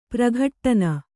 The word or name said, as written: ಪ್ರಘಟ್ಟನ